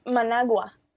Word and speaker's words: Managua: It's Managua.